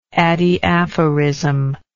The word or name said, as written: adiaphoristic